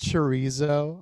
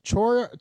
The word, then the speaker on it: chorizo: Mispronounced chorizo. I'm like, charizo? Can I get it? Yeah. Can I get the charizo? – Can I get the huevos?